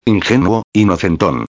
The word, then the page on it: ingenuo inocenton: espanhol